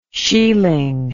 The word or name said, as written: shealing